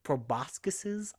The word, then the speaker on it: proboscis: Because, like, at one point they have these big long proboscises.